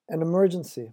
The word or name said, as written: an emergency